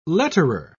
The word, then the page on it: letterer: British English